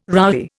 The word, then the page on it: roughy: Indian